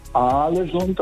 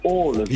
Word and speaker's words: Ålesund: Also das heißt nicht Alesund, das heißt Ålesund.